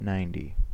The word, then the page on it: ninety: American English